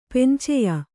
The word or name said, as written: ಪಿಂಚೆಯ